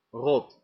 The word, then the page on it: rot: American English; GenAM; [ɹɑt]